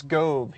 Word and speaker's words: Gov: It's Gove. He always writes his emails. Like, you'll find him on internal aliases. People know him just because that, like, in his address it says Gove rhymes with stove.